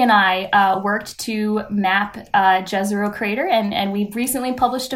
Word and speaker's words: Jezero: We pronounce it Jezero, but I've heard that folks in the Bosnia-Herzegovina area say Jezero.